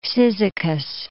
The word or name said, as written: Cyzicus